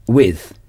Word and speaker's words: with: Come s'écrit C-O-M-E. To come. Avec se dit with. With. With s'écrit W-I-T-H. Faites attention. Prononcez le T-H. With. With. Avec la langue entre les dents.